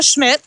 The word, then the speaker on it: Schmidt: Yeah, it's Leah Schmidt, S-C-H-M-I-D-T.